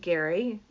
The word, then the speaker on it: Gerry: He also writes for All Things Cruise, G-E-R-R-Y, pronounced Gary, but spelled G-E-R-R-Y.